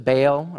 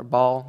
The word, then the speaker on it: Baal: He walked in and he saw shrines in the temple of God to Baal or Baal. There's a couple different ways of pronouncing it.